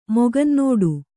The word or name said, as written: ಮೊಗಂನೋಡು